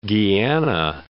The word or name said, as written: Guiana